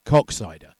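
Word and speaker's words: Koksijde: And for any 14-year-old boys listening, yes, Coxsider is pronounced Coxsider.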